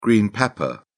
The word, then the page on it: green pepper: British English